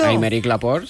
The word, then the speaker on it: Aymeric Laporte: Aymeric Laporte, sí.